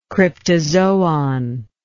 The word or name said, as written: cryptozoon